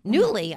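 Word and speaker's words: Nuooly: Rosalie Robinson is the founder of Newly. That's a new word in your outline, in my outline. – It is. – In your bio. – It is. – Newly.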